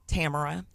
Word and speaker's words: Tamara: How do you pronounce it for real, though? – Tamara. – Tamara? Okay. – Yeah. Like it's spelled.